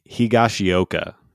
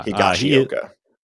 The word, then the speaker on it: Higashioka: I'm going to butcher this last name. – Okay. – Kyle Higashioka. – Higashioka. Yeah. – Higashioka.